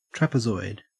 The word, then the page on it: trapezoid: Australian English